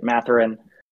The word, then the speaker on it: Mathurin: And the next up on your list, I think I saw, I don't know how to pronounce his last name, but Ben Mathurin? – Yeah, that's right. Mathurin.